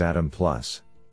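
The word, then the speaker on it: Adam+: In this paper, we propose a new method named Atom Caret Plus, pronounced as Atom Plus.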